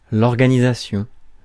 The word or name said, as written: organisation